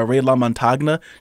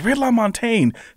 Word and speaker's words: Ray LaMontagne: He goes, yeah, it's like, and I'll take this CD by Ray LaMontagne. She goes, it's Ray LaMontagne.